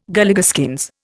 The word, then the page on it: Galligaskins: Indian